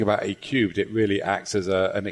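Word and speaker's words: A³: A-Cubed? – Yeah, A-cubed. Well done, yeah.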